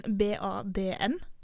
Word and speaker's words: barn: Og så har vi en del ord som slutter på RN, for eksempel barn. – Ja, og det uttales da bad. – Nesten som en BADN? – Ja, nesten.